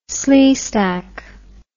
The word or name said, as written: sleestack